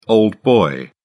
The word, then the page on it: old boy: British English